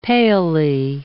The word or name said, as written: palely